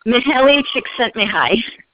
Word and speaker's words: Mihaly Csikszentmihalyi: How do you say that guy's name who wrote the book on flow? – Mihaly Csikszentmihalyi. – Thank you for that.